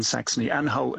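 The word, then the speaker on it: Saxony-Anhalt: And so the one in Saxony, Anhalt, and your pronunciation was, I think, correct, was is of course that it's former Eastern Germany.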